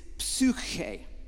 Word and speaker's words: psuche: The word for soul is, when it's translated into Greek, is psuche. You have to spit twice to say that. You hack up a psuche. And it's P-S-U-C-H. Ada, which is kind of like a long E, I guess, and make it A sound.